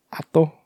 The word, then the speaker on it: Hato: Hato? There's a silent H, Hato.